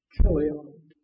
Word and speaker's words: Chilion: I used to pronounce that Chilion until I found out that the Hebrew language doesn't have a ch sound. It's Chilion. So, she was left with them.